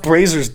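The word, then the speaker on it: Brazzers: It's pronounced Braziers, dumbass.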